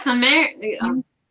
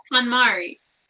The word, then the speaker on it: KonMari: KonMari. Yeah. I can see it spelled, and then I'm like, wait, I've never actually said it out loud. KonMari.